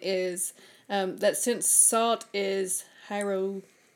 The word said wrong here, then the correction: hygroscopic: hold on, how do you say it? Hygroscopic